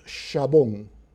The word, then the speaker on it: jabón: For instance, the modern word for soap in Spanish, many of you who know some Spanish know it, jabón, was written then with an X, and it was pronounced shabón.